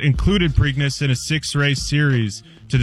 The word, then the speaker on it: Preakness: The Preakness? Preakness? – The Preakness.